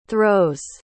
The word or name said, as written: throws